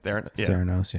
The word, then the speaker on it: Theranos: Theranos. So. – Yeah. – Is that how you say it? – Theranos, yeah.